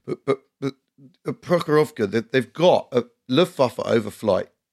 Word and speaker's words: Prokhorovka: Prokhorovka, I think is how you pronounce it.